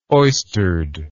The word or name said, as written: oystered